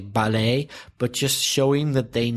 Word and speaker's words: ballet: Another word, B-A-L-L-E-T. In British English, ballet. – And in American, stressing the second syllable, ballet.